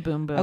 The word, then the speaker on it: Bum Bum: It is called the Boom Boom. – Okay. It's spelled Bum Bum. Yeah, so that's confusing.